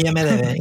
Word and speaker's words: IMDb: Bueno, y pasa con el cine igual, con Film Affinity y IMDb, ¿cómo se dice? IMDb. – IMDb, Internet Movie Database.